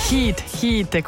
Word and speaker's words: Hit: Hit, questa è la pronuncia giusta.